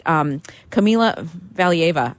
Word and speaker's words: Kamila Valieva: Kamila Valieva, I think that's how you say her name.